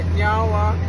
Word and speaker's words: nyawa: So in our language, we say nyawa, which means thank you. – Nyawa. I said it right? – Yeah, you did.